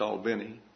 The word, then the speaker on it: Albany: And if you're from Albany, you know that that's pronounced Albany.